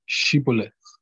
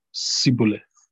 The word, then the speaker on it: Shibboleth: And they would say, "Say Shibboleth." But he would always say Sibboleth. He couldn't say it right.